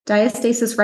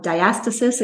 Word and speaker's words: diastasis: So diastasis recti or diastasis, it can be pronounced different ways, involves the abdominal muscles.